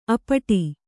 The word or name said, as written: ಅಪಟಿ